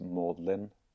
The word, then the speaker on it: Magdalen: Ja, altså Det var jo fordi prins Wales hadde vært ved, ved, det som på engelsk i Oxford uttales Maudlin College. Magdalen, skrives det.